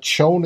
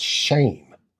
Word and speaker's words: Schoen: And I also heard that it's not Schoen, it's Shane is the proper pronunciation.